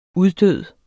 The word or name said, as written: uddød